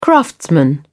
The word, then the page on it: craftsman: British English